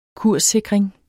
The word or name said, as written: kurssikring